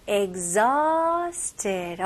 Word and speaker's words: exhausted: exhausted. exhausted. 여기는 스펠링하고 다르게 발음이 정말 달라요. 여기서는 exhausted. 여기서는 약간 가벼운 G 발음에다가 Z로 넘어가요. exhausted. 해볼게요. exhausted. I'm exhausted. exhausted. 강세는 두 번째 음절이에요.